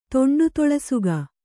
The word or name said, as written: ತೊಂಡುತೊಳಸುಗ